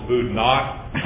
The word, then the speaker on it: Boudinot: And I did want to tell Mr. Secretary that Tom Barney did tell me not to be a Richmond Rube and call this Boudinot. We have a Huguenot bridge here in Richmond but in Northern Virginia it's the silent T, correct? – That is correct.